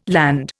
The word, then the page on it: Land: Indian